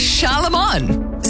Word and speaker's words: Charlemagne: It's Charlemagne. Say it right.